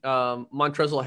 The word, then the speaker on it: Montrezl: Montrezl Harrell. – Montrezl. – I said that Montrezl. Montrez. – No, the L is silent. It's like lasagna.